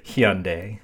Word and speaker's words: Hyundai: Hyundai. that is how you're supposed to pronounce it.